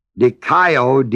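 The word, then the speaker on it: dikaio (δικαιόω): The verb form of righteousness is the Greek word dikaio, D-I-K-A-I-O-O, long O, I told you.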